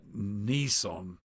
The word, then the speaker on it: Nisan: Now, the word fully come, it was 50 days after the Passover, the 16th day of Nisan. Maybe you would pronounce that Nissan. It's something like the name of a car or truck that we have in the United States.